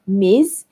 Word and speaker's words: Ms.: Ms. That's pronounced M I Z, Ms. And then Mrs. with her surname.